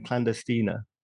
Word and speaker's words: Clandestina: So tell me about your comedy night then, Clandestina.